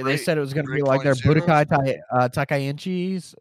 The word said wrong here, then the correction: Budokai Tenkaichi: They said they're bringing back the gameplay, Budokai Tenkaichi.